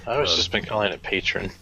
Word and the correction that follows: Patreon: But I guess it is Patreon. – It's Patreon, and you become a patron by using Patreon.